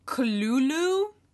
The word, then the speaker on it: Cthulhu: It says Cthulhu is pronounced Cthulhu. This is in a letter written by Lovecraft to an amateur writer, Dwayne W. Rimmel, explaining how to pronounce it.